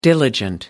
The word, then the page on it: diligent: American English